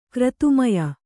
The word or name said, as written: ಕ್ರತುಮಯ